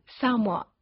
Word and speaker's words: Samoa: We call it Samoa in、 yeah. – the United States. Okay. How do you pronounce it? – Samoa.